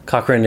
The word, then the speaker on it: Cochrane: And Cochrane is it's pronounced like Johnny Cochrane, but it's got an E at the end.